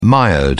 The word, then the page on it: mired: British English